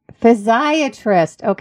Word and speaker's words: Physiatrist: Physiatrist.